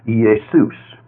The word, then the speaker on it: Iesous: In Greek, his name was written I-E-S-O-U-S and pronounced Iesus.